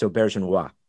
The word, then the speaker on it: Auberjonois: First, let me correct your pronunciation. It's Auberjonois.